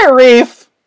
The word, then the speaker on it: NIIRYF: And you can find this podcast, this very podcast, on Twitter at NIIRYFpod, which is the letters for Not If I Reboot You First, and it is pronounced Nairief!